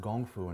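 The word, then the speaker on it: Gong Fu (功夫): Well, you know what's interesting, and I always tell people this, Kung Fu, pronounced Gong Fu in Mandarin, is translated as being a skill acquired over time.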